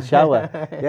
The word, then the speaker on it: Chichewa: Uh, help me with the pronunciation. What language is Chichewa. it? Chichewa.